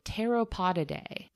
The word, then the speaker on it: Pteropodidae: The greater flying fox belongs to the taxonomic family called Pteropodidae. That starts with a silent P, by the way. – Oh, okay. – Just like pterodactyl, actually.